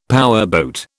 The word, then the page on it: powerboat: British English